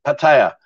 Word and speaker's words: Pattaya: I've got a guy on Discord saying to me, oh, you pronounce Pattaya wrong. It's Pattaya. Well, there's no I and there's no E. Pattaya.